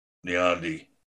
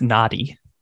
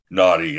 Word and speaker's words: Nnadi: Then you got, Derek Nnadi. – That's pronounced Nnadi. – Nnadi, yeah, exactly.